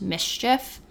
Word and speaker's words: MSCHF: I think it's pronounced Mischief. M-S-C-H-F.